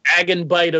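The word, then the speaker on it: agenbite: I'm not sure how to pronounce the first word. Is agent bite or agon? – Agon bite of inwit. – That's how you pronounce it. That's it.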